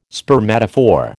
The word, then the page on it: spermatophore: American English